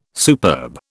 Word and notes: superb: British English

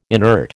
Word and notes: inert: American English

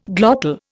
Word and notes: glottal: Indian

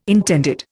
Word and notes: intended: Indian

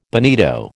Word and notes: bonito: American English

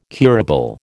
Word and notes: curable: American English

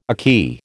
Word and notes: ackee: American English

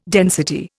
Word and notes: density: Indian

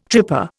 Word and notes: tripper: Indian

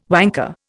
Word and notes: wanker: Indian

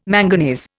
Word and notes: manganese: Indian

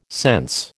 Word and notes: sense: American English

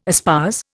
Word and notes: espouse: Indian

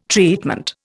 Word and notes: treatment: Indian

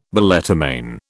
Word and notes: balletomane: British English

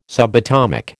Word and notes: subatomic: American English

